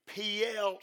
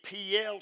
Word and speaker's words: Piel: But every time but one, it is expressed in the P-L stem. That's spelled P-I-E-L, the P-L stem.